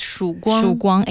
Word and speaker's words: Shuguang: I think it's named Shuguang, if I'm pronouncing it correctly. – In Chinese, it's Shuguang 8000.